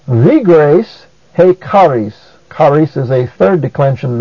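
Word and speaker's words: he charis (ἡ χάρις): Also notice that he is a proclitic. That is, it goes so closely with the word that follows as to have no accent of its own. Therefore, it is pronounced he charis.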